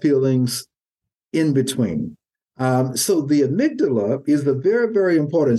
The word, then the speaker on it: amygdala: And that structure is called the amygdala, amygdala. And you spell that A-Y-A-M-Y-G-D-A-L-A, A-M-Y-G-D-A-L-A, the amygdala.